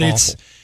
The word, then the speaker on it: offal: O-F-F-E-L. It sounds like the word awful. – I think it's A-L, O-F-F-A-L, yeah. Okay, but awful.